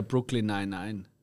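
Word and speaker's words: Brooklyn Nine-Nine: Ich glaube, am meisten kennt man ihn bei uns aus äh, Brooklyn 99.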